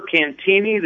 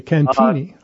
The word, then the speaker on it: Cantigny: Okay, so this is an entire parkland, as you're mentioning, in Cantigny Park, is it called? – We prefer Cantini. The G is silent. – Okay, Cantini.